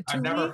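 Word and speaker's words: Thule: Because you see all these other people like with, I don't know if I'm going to pronounce this right, thul, T, I never, oh yeah, the tuli? – There we go.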